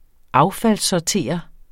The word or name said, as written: affaldssortere